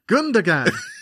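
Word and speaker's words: Gündoğan: Gundogan. It's because he's Turkish descent, so the G is a soft G.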